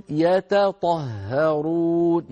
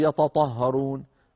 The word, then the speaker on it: يتطهرون: إنهم أناس يتطهرون، يتط أيضا هنا التقى في النطق تاء وهي مرققة وطاء وهي مفخمة، فلا بد من بيانهما، يتط يتط، ولا نقول يتطهرون.